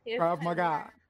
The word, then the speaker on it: Krav Maga: Did I say that right, Krav Maga? – Krav Maga.